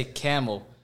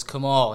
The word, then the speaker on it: Kamal: People say Camel. When I was a kid growing up, people would always call the house and like, "Can I please speak to Mr. Kamal?" I'd be like, "No, it's Kamal," and then hang up on him.